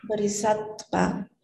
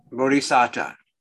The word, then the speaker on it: bodhisattva: Sravaka Buddha, Pratika Buddha, and the real how do you pronounce that? – Bodhisattva. Bodhisattva. I always say bodhisattva. – Bodhisattva. – But I don't know where you get the D.